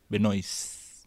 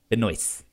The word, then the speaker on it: Benoist: Without the last T, Benoist. – Benoist. – Yeah. – Okay. – At least that's how she pronounces it.